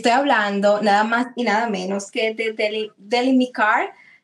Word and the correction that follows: Demelicar: Escobar lo dije bien, de Melicar.